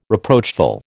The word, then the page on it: reproachful: American English